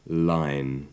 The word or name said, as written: line